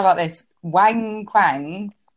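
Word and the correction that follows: Wang Qiang: number three. – Wang Chang. – Okay, Wang sorry. Chang.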